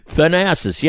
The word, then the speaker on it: Thanasis: Thanasis.